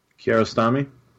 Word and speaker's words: Kiarostami: Kiarostami.